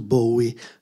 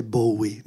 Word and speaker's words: Bowie: Claudio, ho visto che la prossima puntata è ancora su Bowie. La pronuncia è Bowie, non Bowie, please.